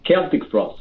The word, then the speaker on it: Celtic Frost: Is it Celtic Frost or Celtic Frost? Because I've heard Tom say it either way. How do you say it? – Celtic Frost. – Celtic Frost. Okay.